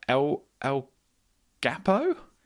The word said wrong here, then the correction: Guapo: Yes, it means handsome. – Oh, thank you. – Is it Guapo? – Guapo? Yeah. Guapo.